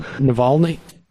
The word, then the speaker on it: Navalny: I mispronounced Navalny today. Matt had to walk me through it beforehand, and I just can't get it. Did I say it just right then? Navalny? – Yep, I think that's right.